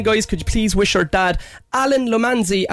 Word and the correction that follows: Lomasny: a fantastic birthday today. Is that pronounced correct? – Alan Lomasny, I guess, yeah. – Lomasny, yeah. That's what I said.